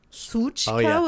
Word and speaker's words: Sushka: Which I found out that I actually pronounce incorrectly. – Okay. – But it was a nickname that some people that I used to work with learned from some Russians. And that's the way we pronounced it. But apparently it's supposed to be more of a Suchka with a TZ Suchka. Kind of a Z.